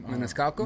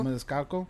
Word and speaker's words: Maniscalco: Maniscalco. – Maniscalco.